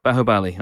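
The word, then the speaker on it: Baahubali: Bahubali, okay. The beginning. and The beginning. – Bahubali. Bahubali 2, The Conclusion.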